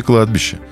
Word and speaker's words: кладбище: И чаще всего это не Красная площадь, не ГУМ, не ЦУМ, и не Детский мир, и не Новодевичье кладбище. Хотя этот перечень все по-прежнему выкрикивают зазывалы на площади 3 вокзалов, иногда ставя неправильное ударение в слове «кладбище». Кладбище.